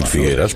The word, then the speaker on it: Figueres: Figueres, puedes decir Figueras porque es una E abierta. Y cuando se pronuncia en catalán, se pronuncia igual que en castellano.